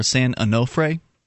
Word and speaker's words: San Onofre: San, apparently I was mispronouncing it before, San Onofre.